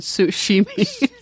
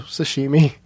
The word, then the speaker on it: sashimi: Oh, I thought you said sushimi. – That's a fun way to pronounce it, but no, sashimi.